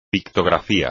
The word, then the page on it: pictografía: espagnol